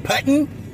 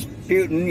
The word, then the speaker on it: Putin: Uh, you, you, you Russian guy, you, you putting, putting, you do this. It's Putin, you know, come on, Biden, say his name right.